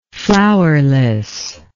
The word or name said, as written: flowerlessness